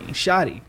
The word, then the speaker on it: Shaadie: Shaadie.